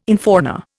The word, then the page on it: infauna: Indian